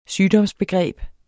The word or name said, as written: sygdomsbegreb